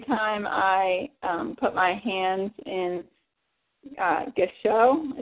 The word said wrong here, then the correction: gassho: is that how you say it? Oh, gassho?